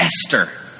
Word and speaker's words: Esther: So if you have your Bible, turn to Esther. The H is silent. Esther.